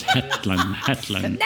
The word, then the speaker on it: Hetland: L-E-I-F-H-E-T. – L-A-N-D. – Hetland. – It's okay. – Like a Shetland pony without the S. Hetland.